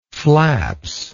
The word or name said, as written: flaps